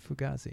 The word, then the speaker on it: Fugazi: Is that how you pronounce it? – Fugazi. – I always called it Fugazi. – It's Fugazi.